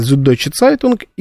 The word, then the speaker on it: Süddeutsche Zeitung: Значит, читается Süddeutsche Zeitung и Die Zeit.